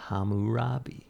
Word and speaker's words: Hammurabi: Hammurabi.